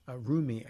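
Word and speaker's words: Roumie: Jonathan Rumi, I think is how you pronounce his last name.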